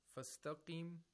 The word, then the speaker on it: Fastaqim: Fastaqim, we have a qaf with a kasra. Fastaqim, we have to pronounce the qaf with the lowest level of tafkhim.